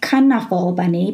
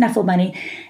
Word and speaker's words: Knuffle Bunny: It's Knuffle Bunny, or if you pronounce it Knuffle Bunny, which I have found out that's how Mo Willems actually pronounces it. Of course, he's the author. And he says on his website that it's pronounced Knuffle Bunny because in Dutch, knuffle means like to snuggle or hug.